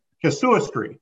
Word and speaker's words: casuistry: Casuistry, right? – How do you spell that? – C-A-S, right?